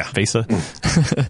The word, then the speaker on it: VESA: It's like, really, the people over at VESA, did I pronounce that right? – Yeah. – VESA.